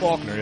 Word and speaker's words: Faulkner: Faulkner, yeah.